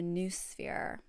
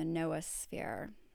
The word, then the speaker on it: noosphere: So he came up with this idea of the noosphere or the noosphere. It's spelled N-O-O-S-P-H-E-R-E.